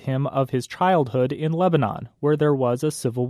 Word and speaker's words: Lebanon: The search result came back with Lebanon, Oregon, and that led him to discover the more than 40 Lebanons throughout the United States. And a quick note about pronunciation. Bukaram says most of these cities in the U.S. are pronounced Lebanon versus the country Lebanon.